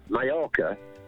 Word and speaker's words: Mallorca: Two L's in Spanish. – Yeah. – You pronounce yeah, yeah. – Right. – Yeah. Like the Y sound. So Mallorca is just spelled double L.